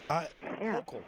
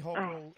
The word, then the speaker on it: Hochul: Melissa, Hochul, Kathy Hochul, remember Hocus Pocus.